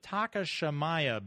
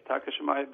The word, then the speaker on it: Takashimaya: And I've learned how to say the building. It's the Takashimaya building, right? – Good evening. Yes, Takashimaya building on Fifth Avenue.